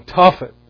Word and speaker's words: Tophet: And its very name, Tophet, would be a curse twice over. First by being made to rhyme with Boshet, shame, and hence pronounced as the word for spitting.